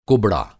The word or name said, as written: कुबड़ा